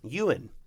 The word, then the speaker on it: Ewan: Ewan. Ewan? – Ewan McGregor.